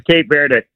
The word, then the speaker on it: Cape Verde: Either way, you said it right. It's kind of like Turkey. – Yeah. – In Spanish, it's Cabo Verde. – Cabo Verde. – Cabo San Lucas or whatever in Mexico. Cabo Verde. But okay. The English pronunciation is Cape Verde.